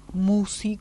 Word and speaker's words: Mussi: El programa lo subimos al canal de YouTube que lleva mi nombre, Marisa Musi. – ¿Cómo se escribe Marisa Musi? – Marisa con S, Musi con M-U-S-S-I.